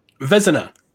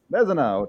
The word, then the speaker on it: Vezina: Goalie, is it the Vezina? How do you say that? – Vezina. – Vezina, a trophy for the goalie.